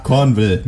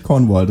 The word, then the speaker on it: Cornwell: So, Bernard Cornwell mit E. Nicht Cornwall. Das ist, ich habe das richtig ausgesprochen für alle, die da draußen an den Hörern schon mosern.